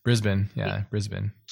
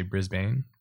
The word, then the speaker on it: Brisbane: One of the big breaks was the analog digital, the Brisbane. Am I pronouncing it right? I'm my American. – Brisbane. – Okay. Yeah. Cause I would say, I think in America, we'd say Brisbane.